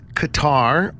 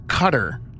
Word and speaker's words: Qatar: It can either be pronounced Qatar or Cutter. So the answer is, I'm going to go with Qatar. – And I say Cutter. Like NASCAR style.